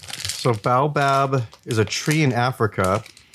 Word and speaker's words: Baobab: Baobab. Am I saying that right? Baobab. – Baobab.